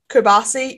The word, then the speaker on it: Qubaisi: In the morning session, it was um, MP Motorsport Hamda Al Qubaisi. I'm so sorry if I have mispronounced Kubasi. it. – Kubasi,